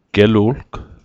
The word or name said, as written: gelu'lg